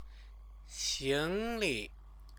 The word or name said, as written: xingli